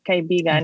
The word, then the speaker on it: kaibigan: And friend in Tagalog is kaibigan. Mm-hmm. Did I say that right? – Yeah, kaibigan.